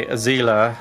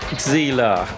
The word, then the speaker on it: Xela: Picked up some from the playlist here by Zela with an X-E-L-A. It's always impossible to pronounce the X. It always comes out as Z or Z, doesn't it, my friends? Zela.